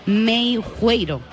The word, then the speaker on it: Meijueiro: Y para darnos más detalles, esta tarde nos acompaña Luis Meiu, que tengo que pronunciarlo bien, Luis, porque me he prometido a mí misma que iba a decir bien tu apellido, Meijueiro, que es un apellido un poco complicado. – Sí, está bien.